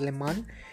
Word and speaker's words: Le Mans: Tengo entendido que la S al final no se pronuncia, así que es Le Mans.